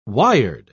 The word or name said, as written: wired